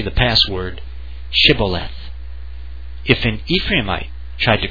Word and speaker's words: Shibboleth: And when he said, 'No,' they said to him, 'Then say Shibboleth.' And he said, 'Sibboleth,' for he could not pronounce it.